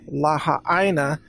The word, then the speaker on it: Lahaina: And by the way, I believe I'm pronouncing it correctly, Lahaina. I think Americans tend to call it Lahaina, just like they say Maui instead of Maui.